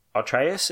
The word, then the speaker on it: Atreus: I mean, it makes sense that, and I'm probably going to butcher their names, but Atreus, is it? – Yeah. – Yeah, it is, you know, the secondary character.